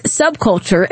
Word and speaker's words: subculture: He says, Kim, I believe the word you are looking for is subculture, emphasis on the sub.